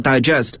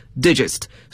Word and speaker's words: digest: 大家都知道它做名词时还可以表示文摘，但这个文摘呢就不能读作 digest, 而要读作 digest, 所以拼写相同，可是发音词性各不相同。